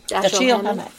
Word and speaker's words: Dashiell Hammett: Dashiell Hammett. – Dashiell Hammett.